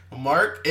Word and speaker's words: Mark: Mark M A R K and M A R C are two different things, all right?